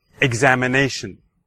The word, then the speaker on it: examination: Examination. Examination. All right, so which syllable did you hear the stress? All right, well, hopefully you heard it in the examination, the fourth syllable, the nay.